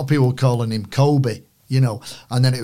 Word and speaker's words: Kobbie: He said, it's Kobbie. And I went, as in Cobb. And he went, absolutely spot on. So I said, Kobbie, mate.